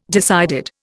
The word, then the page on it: decided: Indian